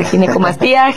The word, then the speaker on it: ginecomastia: Pues muchas gracias, y sí, definitivamente creo que es una, para empezar la palabra, le decía, ¿cómo se pronuncia? Ginecomastia. – Sí, exacto, ginecomastia.